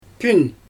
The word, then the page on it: pün: Xyzyl